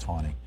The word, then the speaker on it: Taney: And by the way, Roger Taney pronounced his name as if there was a W in it. It was pronounced Taney.